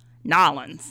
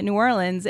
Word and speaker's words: New Orleans: I'm originally from New Orleans, and yeah, you hear me how I'm pronouncing it, New Orleans, and every time I tell people that, I get one of three things.